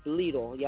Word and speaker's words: Lidl: That's how you pronounce that store. Lidl's.